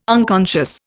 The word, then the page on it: unconscious: Indian